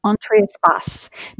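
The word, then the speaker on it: Entre Espace: Entree Escapes. I hope I'm pronouncing that correctly. – It's Entree Espace, Between Spaces.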